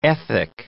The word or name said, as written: ethic